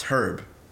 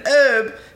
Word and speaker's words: herb: It's herb. I played Resident Evil 5 and Sheva said, I need an herb. I'm like, bitch, there's an H. – It's an herb. – It's herb. – The H is silent.